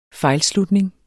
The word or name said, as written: fejlslutning